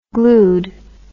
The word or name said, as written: glued